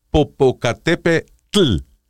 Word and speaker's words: Popocatépetl: Te el volcán Popocatépetl, porque es una it's a very special pronunciation. Tiene que decir Popocatepetl Popocatépetl sea, you have to do your tea like from the back of, of the.